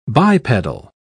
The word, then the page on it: bipedal: British English